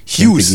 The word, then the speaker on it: Hughes: Maxi Huge, Huge, falls er so heißt. – Hughes, Hughes. Hughes. Glaube ich, spricht sich das aus. Ja, ja, ich weiß, wie Mark Hughes, der, der Fußballer früher, ah, okay.